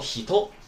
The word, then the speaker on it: 人: 所以说这个人读什么呀？是不是读成 Jin 呢？那么我们第二课的时候是不是学了一个单词啊？人叫 Hito，对吧？是不是人呢？那么你说这两个单词的话。一个人读成进，一个人读成 hito，那么这两个的话，哪一个是音读，哪一个是训读啊？